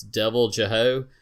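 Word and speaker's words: Deviljho: I'll try to say the name, and I'll spell it. It's Devil Jeho. It's Devil and then J-H-O.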